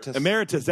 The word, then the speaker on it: Emeritus: So his office was in the library when he, I think while he was president, while he was president, Emeritus. I'm not sure how to pronounce that second part. – Emeritus, that's it.